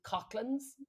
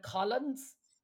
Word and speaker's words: Coughlans: No, just that I the pub that we were in is absolutely called Coughlans, but they all call it Collins. They just don't pronounce it. It's C O U G H L A N S. Coughlans. Yeah. And they were like, it's Collins. And I was like, you, this is why people from Cork have the reputation that you have.